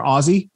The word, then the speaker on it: Aussie: It's pronounced Aussie with Z's, but it's spelled Aussie with S's.